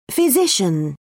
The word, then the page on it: physician: British English